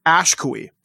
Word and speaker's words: Ashkui: Actually, you're actually pronouncing it very close to the way it's pronounced in Ojibwe and what that means in old Ojibwe, which is how you're very close to pronouncing it, means the one who is last standing. But the way I pronounce it is Ashkui.